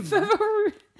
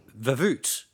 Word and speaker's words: Vervoort: Verroot. Okay, how would you pronounce this? V-E-R-V-O-O-R-T. – Verroot.